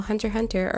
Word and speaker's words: Hunter x Hunter: So, for those of you who don't know, Hunter Hunter or Hunter X Hunter, for you special few who uh, like pronounce the X, um, even though you're not supposed to, it's uh, Hunter Hunter actually had an original series that came out back in like the 80s.